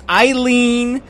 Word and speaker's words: Aline: Her entire name is mispronounced. It's Eileen. There's Aline. – No, there's an A there. It's A-L-I-N-E.